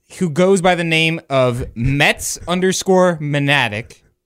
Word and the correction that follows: Maniac: or Maniac, I'm sorry.